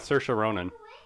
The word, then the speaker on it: Saoirse Ronan: I went with Saoirse Ronan.